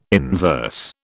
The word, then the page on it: inverse: British English